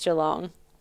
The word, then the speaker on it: Geelong: Pronounced Geelong.